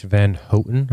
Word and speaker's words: Van Houten: Carice Van Houten, I think is how you say it. H-O-U-T-E-N.